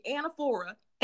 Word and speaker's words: anaphora: Hey guys, so this is anaphora, and you guys pronounce it after me, anaphora.